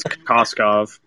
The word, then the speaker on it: Kokoskov: Kukovic? I probably botched that, so I hope. – I think it's Kokoskov. – Kokoskov, okay. Yeah, that sounds more like it.